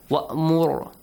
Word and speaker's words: وَأْمُرْ: Only the letter Ra has to be pronounced correctly. وَأْمُرْ heavily. وَأَعْرِضْ رِ lightly.